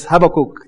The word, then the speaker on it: Habakkuk: right, let us open to the book of Habakkuk. Yes, Habakkuk, the biblical pronunciation.